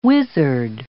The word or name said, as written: wizard